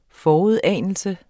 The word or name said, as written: forudanelse